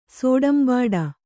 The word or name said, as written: ಸೋಡಂಬಾಡ